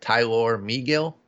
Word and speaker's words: Tylor Megill: I'm pretty sure that this is how you pronounce it. Tylor Megill. – Mm-hmm. That's correct. – I don't think it's just Tyler.